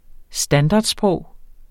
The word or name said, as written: standardsprog